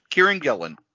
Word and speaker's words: Kieran Gillen: And this is built on, how do you say the creator? Kieran Gillen. – Yep, Kieran Gillen.